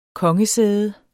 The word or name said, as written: kongesæde